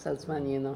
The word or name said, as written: Salzmanino